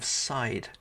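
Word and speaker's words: seiðr: It's like a side of meat, side, as in not the front, not the back, but the side.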